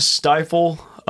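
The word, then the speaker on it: Stifel: performing, you opened up for Bailey Zimmerman at, do you pronounce it the Stifle Theater? – The Stifle. I've heard people say it's a Stifle. I think they're wrong.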